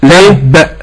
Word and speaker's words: تب: اللي هي الزمن زي تب، يعني أختها كلمة تب في سورة المسد، هل يصح أن يقول القارئ وتب؟ – نعم. – ولا لازم يقول تب؟ – تب، لأن الباء مشددة.